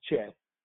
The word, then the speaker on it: cheth: you'll see that you'll see something that says C-H-E-T-H. It looks like it says chet. It's pronounced with that C-H like the C-H in Bach. So you're going to say chet. Some people will just pronounce it with the H, and that will be what it is.